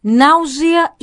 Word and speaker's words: nàusea: Nàusea i nàusees, perquè els dos vull comentar, perquè feu bé la pronúncia amb S sonora en català i no pas amb S sorda.